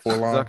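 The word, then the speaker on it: 4-LOM: Did I say that right? Forlom? – Zuckus. – Is that... – Yeah. – No, I meant the Forlom. – Oh, Forlom. Yeah. You got it. Yeah, Forlom's right.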